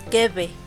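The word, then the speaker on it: kibbeh: Eh, se puede, el kipi crudo, que se puede comer como un tipo carne tártara, y se pronuncia kipi, pero, bueno, aquí así lo decimos, pero la pronunciación como tal es kebe.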